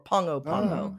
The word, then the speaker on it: Pago Pago: It looks like Pago Pago, P-A-G-O, P-A-G-O, but it's pronounced more like Pongo Pongo.